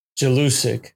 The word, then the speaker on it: Jelusic: Yeah, so it's Marco Mendoza, Dino Jerlicic. – Yeah. – I pronounced that wrong. Jelusic. Okay.